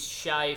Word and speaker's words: Shea: My real name is Shay Faithful, and it's spelled S-H-E-A. So when kids learn to spell, they call you Shea.